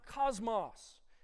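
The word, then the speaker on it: kosmos: This word world in the Greek is pronounced cosmos.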